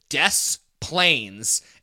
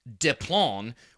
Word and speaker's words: Des Plaines: Because it is spelled D-E-S Plains. – Yeah. – And our dumb Midwestern asses pronounce it Des Plains and not Deplon, which is what it would be because it's French, because it was discovered by a, well, quote unquote discovered, colonizing, whatever, but by a French explorer who named it.